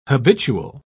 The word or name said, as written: habitual